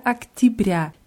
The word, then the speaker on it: октября: Октября. Wie Sintiabri auch, hat Октябрь ein Ja in der Mitte, das wird aber wie ein I ausgesprochen, weil die Betonung auf dem letzten Ja ist.